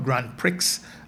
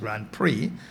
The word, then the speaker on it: Grand Prix: It's the first Guyana Grand Prix, not Grand Prix, as some people are saying. It's P-R-I-X, but it's pronounced Grand Prix.